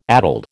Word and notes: addled: American English

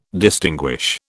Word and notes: distinguish: British English

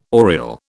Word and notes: oriel: British English

